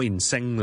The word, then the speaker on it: insane: 再俾多个例子大家，譬如 insane，冇理智嘅一个人，insane，I-N-S-A-N-E，就唔好 insane 啦，not sing as ing，no，it's say，S-A-Y。